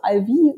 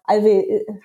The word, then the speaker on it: Alve: Also ja, tatsächlich wird es ganz unterschiedlich ausgesprochen, je nachdem, ob du im englischsprachigen oder deutschsprachigen Raum bist, also Alvi oder Alve, aber Alve hat sich jetzt doch relativ durchgesetzt.